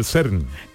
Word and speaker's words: CERN: SEN, acompañada de referentes tan importantes como el doctor Barney, que ella lo pronunciará mejor que yo. ¿Perdón? – Cuenta que es el CERN.